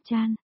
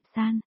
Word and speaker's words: thiện: Thiện, chú ý, ở đây không phải là phát âm chan, thiền, mà ứng với phát âm là san, thiện, tế địa tại gò đất dưới Thái Sơn.